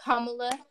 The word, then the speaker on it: Kamala: It's Kamala. You were right. But I was wrong. – Vice President. – So Kamala.